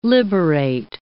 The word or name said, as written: liberate